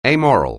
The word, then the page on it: amoral: anglais